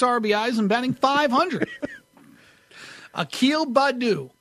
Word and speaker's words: Akil Badu: Akil Badu. – Akil Badu. – I hope I'm saying it right. His first name, A-K-I-L, that's Akil. – Akil Badu, yeah. – Badu, B-A-D-D-O.